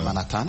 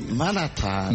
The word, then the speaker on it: Manhattan: Mais une fois arrivé sur place, la prononciation change. Quand on dit Manhattan... – Manhattan.